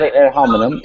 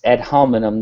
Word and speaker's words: ad hominem: It's not ad hominem. – It's ad hominem, not ad hominem.